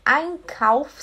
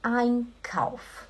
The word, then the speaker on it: Einkauf: Einkauf seria compras. Sempre quando você vê o E e o I junto, ele faz som de ai. Lembra do Einstein, Albert Einstein, né? Você fala Einkauf, ok?